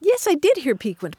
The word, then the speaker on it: piquant: I mean, we were talking about the word piquant. You mm-hmm. used it a couple of weeks ago. And I had always heard it pronounced piquant. – And so here at this point of your life, you're ready to make the change to the proper pronunciation? – Well, I did find that in one dictionary, the Merriam-Webster, it's the third pronunciation they offer.